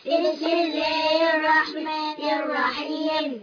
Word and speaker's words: الرحمن: ألف وسط في وسط الكلام لا ينطق لام شمسية مضغمة لا تنطق راء شدة وفتحة مفخمة. – بسم الله الرحمن.